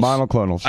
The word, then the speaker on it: monoclonals: I said, after you've got your mycoclonals. – Monoclonals. – I know, you know how to say it.